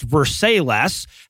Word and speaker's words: Versailles: And in Rebuild That Wall news tonight, the town of Versailles, Kentucky, which, given the state it's in, is probably pronounced Versailles, has decided to disregard.